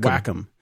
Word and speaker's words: Wacom: When I go to SIGGRAPH, I'd go to the booth and they would always chastise me and say it's called Wacom. – Really? Like Wacom-mole. Did not know that. That is one pronunciation I never would have guessed.